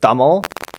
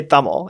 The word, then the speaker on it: Tamil: This grandmother who's supposed to be from India, like you don't pronounce Tamil T- Tamil. And I think that's how she says it. She, you're supposed to say Tamil.